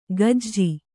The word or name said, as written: ಕಾಜಿ